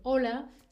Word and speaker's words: hola: Pero si escribes esa palabra sin la H, porque esta, esta eh, palabra se escribe con H, pero no se pronuncia, no se dice hola, no se dice hola, ¿sí?